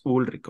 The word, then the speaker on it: Ulrich: Y pues eran bien fans, sobre todo Lars Ulrich de Dinamarca, que es la, la forma correcta de decir su apellido, porque un, chorro de veces nos han dicho, así no se dice Ulrich, no se dice, bueno, es Ulrich, ok, así.